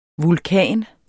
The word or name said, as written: vulkan